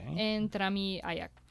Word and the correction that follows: Ayash: Ayash. – Okay. Yeah. Ayash.